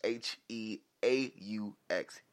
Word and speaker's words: Heaux: Now the way it is actually spelled is H E A U X. Heaux. Tails. And it dropped the top of the new year, January 8th.